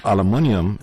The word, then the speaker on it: aluminium: About aluminium, it has two variations of pronunciation: British with a U sound, or American with an O sound.